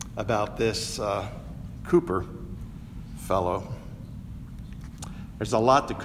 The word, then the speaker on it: Cowper: I remember how old poor, now this looks like Cowper, but it's pronounced Cooper. I remember how poor Cooper,